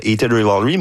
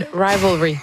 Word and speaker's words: Heated Rivalry: Et évidemment, on a beaucoup parlé dans la dernière année grâce à la trame sonore de Heated Rivalry, mais... Je l'ai bien prononcé, je pense, c'est la première fois. – Non, Heated Rivalry.